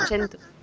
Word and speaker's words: Cento: Non, c'est Cento. Parce que le C se prononce ch.